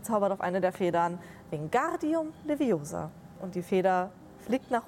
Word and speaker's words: Wingardium Leviosa: Wingardium Leviosa. – Genau, die Betonung äh, auf dem Gar und auf dem O. Ich glaube, du musst das eher nicht rollen.